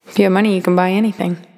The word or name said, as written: If You Have Money